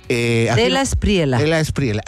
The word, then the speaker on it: de la Espriela: Es que él se llama Tigre, el señor de Espriela. ¿Así se pronuncia? ¿De Espriela? – De la Espriela.